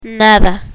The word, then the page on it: nada: Spanish